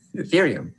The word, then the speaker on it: Ethereum: Erythium? Ethereum. How do you say it? – Ethereum.